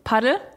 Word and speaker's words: Padel: Ja, also häufig wird ja auch gesagt, dass das in Spanien so ausgesprochen wird. Tatsächlich geht es meistens auch eher in die Richtung Paddel, also wirklich so richtig deutsch ausgesprochen. Aber ich glaube, man kann es nicht falsch sagen. Solange man weiß, was dahinter steckt, sagt, wie ihr wollt.